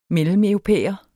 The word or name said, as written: mellemeuropæer